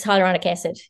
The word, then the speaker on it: hyaluronic acid: And often you see things like, I don't know if I've pronounced it right, hyaluronic acid. – Yes, hyaluronic acid.